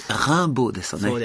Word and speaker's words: ランボー: ちょっと発音違うと思うよ。 – 違うです。あ、間違ったですね。 – よろしくお願いします。ランボーです、ね。 – もう一度正確な発音で。 – そうですね。ランボーですよね。